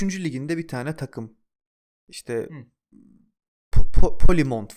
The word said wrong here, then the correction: Plymouth: falan öyle bir tane takım vardı yanlış telaffuz etmiyorsam. – Plymouth.